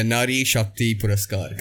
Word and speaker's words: Nari Shakti Puraskar: but still I went, I Googled, I looked at what it was, and I saw this video of you getting the Nari Shakti Puraskar.